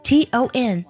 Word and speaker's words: ton: 第一个单词 ton, ton, 那我们的字母 o 的发音呢是小口尖尖啊，它发音的时候一定要短促有力。 t, t, 发音的时候呢，上下齿是一根小拇指的宽度是最合适的。 t, t, 然后加短鼻音 ton, ton。